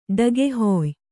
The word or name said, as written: ಡಗೆಹೊಯ್